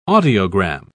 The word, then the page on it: audiogram: British English